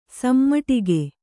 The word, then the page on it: ಸಮಟಿಗೆ: samaṭige